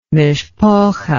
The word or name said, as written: mishpocha